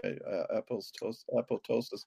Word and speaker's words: apoptosis: Apoptosis.